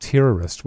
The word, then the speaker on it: tervist: It's actually spelled T-E-R-V-I-S-T, but what I could gather, it's pronounced terrorist, which could potentially lead to some issues.